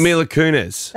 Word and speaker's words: Mila Kunis: Well, did I say the name correctly before? – Mila Kunis. – Mila Kunis. – That is Mila Kunis.